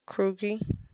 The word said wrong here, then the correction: Kurgi: Krugy. Kurgy. I mean, Kurgy. – Can you spell it? – K-U-R-G-I. – K-U-R-G-I.